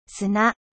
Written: 砂

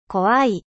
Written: 怖い

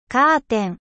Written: rideau

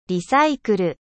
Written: リサイクル

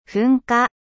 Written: eruption

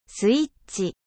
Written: interrupteur

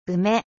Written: うめ

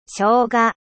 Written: gingembre

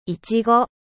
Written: fraise

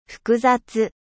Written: complexe

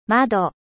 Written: fenetre

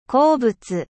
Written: mineral

